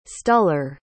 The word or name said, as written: stuller